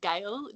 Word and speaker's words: Gale: It's actually Gale, G-A-L-E.